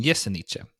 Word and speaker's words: Jesenice: Och det är ju ett slovenskt death metal-band från, jag kan, jag, ursäkta min uttal, eh, mitt uttal på det här, men Jesenice tror jag heter. – Mm. Det, låt, det lät bra.